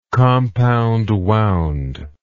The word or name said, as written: compound wound